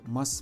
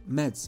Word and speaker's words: Mads: Mas Mikkelsen, cel mai mare actor danez, ever. Mă rog, Mads Mikkelsen, cum pronunță americanii.